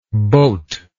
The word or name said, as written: bote